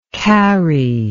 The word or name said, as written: karri